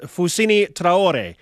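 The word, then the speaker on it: Fousseni Traoré: And for what it's worth, Alex, the more native pronunciation is Fusini Traore.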